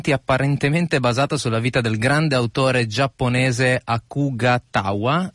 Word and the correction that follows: Akutagawa: I hope to have pronounced it well. Akutagawa, ok.